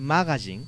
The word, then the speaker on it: magazine: Ahorita, actualmente no sé, pero Shonen Jump y Shonen Magazine. Magazine es pronunciación japonesa, magazine.